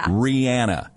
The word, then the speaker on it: Rihanna: I'm saying it correctly right now. – Yeah. – Rihanna. – Mm-hmm. – That's how Rihanna has said that that's how her name is supposed to be pronounced.